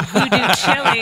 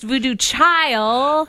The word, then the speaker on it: Voodoo Chile: Which I call Voodoo Chili. It's actually pronounced Voodoo Chile. – Yeah. – But it's spelt like Voodoo Chili.